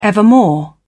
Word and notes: evermore: British English

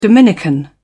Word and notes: dominican: British English

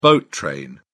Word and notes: boat train: British English

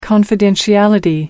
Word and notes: confidentiality: American English

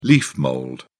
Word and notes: leaf mould: British English

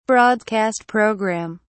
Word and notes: broadcast program: British English

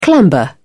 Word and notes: clamber: British English

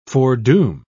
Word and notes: foredoom: British English

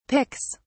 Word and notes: picks: British English

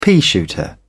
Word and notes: peashooter: British English

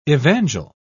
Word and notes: evangel: British English

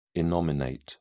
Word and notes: innominate: British English

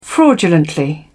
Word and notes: fraudulently: British English

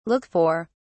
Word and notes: look for: British English

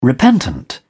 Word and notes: repentant: British English

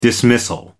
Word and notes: dismissal: American English

